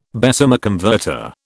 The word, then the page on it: Bessemer converter: British English